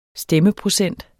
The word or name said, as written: stemmeprocent